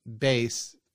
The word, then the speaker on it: Bass: It doesn't make any sense because it's spelled bass like bass, but it doesn't rhyme with it. That was a sticker for fish, not for a bass. – Oh, it's Kiss My Sweet. – Sweet bass. – Bass. – It rhymes more like with face. It's like Kiss My Sweet Face.